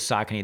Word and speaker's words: Saucony: It is Saucony.